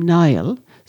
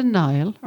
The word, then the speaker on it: Niall: Or maybe it's a variant such as O'Neill, N-E-A-L, or MacNeil, or Neil, or Nielsen. The ancient Irish given name Niall, sometimes pronounced Neil, comes from the Irish for warrior.